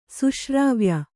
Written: ಸುಶ್ರಾವ್ಯ

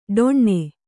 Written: ಡೊಣ್ಣೆ

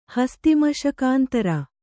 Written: ಹಸ್ತಿಮಶಕಾಂತರ

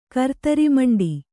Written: ಕರ್ತರಿಮಂಡಿ

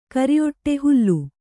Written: ಹಲಿ

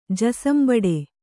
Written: ಜಸಂಬಡೆ

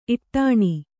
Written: ಇಟ್ಟಣೆ

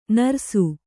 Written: ನೆರಸು

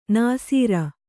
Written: ನಾಸೀರ

